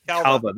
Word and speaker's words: Calva: The great performances from Margot Robbie, Brad Pitt, Diego Sinha. No, I pronounced his last Calva. – Name. Calva, that's what it was.